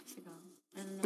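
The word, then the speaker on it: Segel: Segal.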